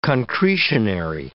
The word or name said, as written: concretionary